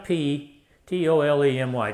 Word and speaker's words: Ptolemy: One of those guys' name was Ptolemy, silent P, T-O-L-E-M-Y, Ptolemy.